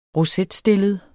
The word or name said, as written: rosetstillet